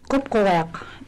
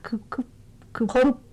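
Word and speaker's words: Qupquġiaq: It's spelled in English Q-U-P-Q-U-G-I-A-Q. – Let me try that. – And that G has a dot over it, which is basically an Inupiaq symbol. Okay. Kupkuwak.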